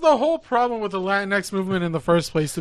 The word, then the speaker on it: Latinx: Do you say Latinx?